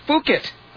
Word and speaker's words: Phuket: I believe this is pronounced Phuket, I would hope. Well, I can say that safely, can't I? – Phuket? – That's correct, yes.